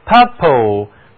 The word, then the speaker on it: purple: 紫罗兰是花的名字，而色彩学上的紫色则是单词 P-E-R-P-L-E purple purple。 U-R 这个字母组合读音呢是长元音紫色 purple。